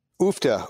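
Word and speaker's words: Uff da: We are currently drinking, correct me on the pronunciation, is it Ufta or Ufta? – Ufta. Ufta is a Norwegian expletive.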